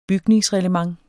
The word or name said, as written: bygningsreglement